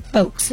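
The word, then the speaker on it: Foulkes: Former CBS executive Helena Folks, pronounced Folks, that is, defeated Governor Dan McKee in yesterday's Democratic primary.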